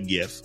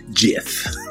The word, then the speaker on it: GIF: It's pronounced GIF because GIF is peanut butter. – So here's the thing. I say GIF, but the dude that created it, he said it's GIF. – He says GIF.